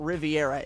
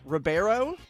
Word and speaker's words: Ribeiro: And I'm a little confused on the last name, how you pronounce it, but it's not Riviera. It's Alfonso Ribeiro.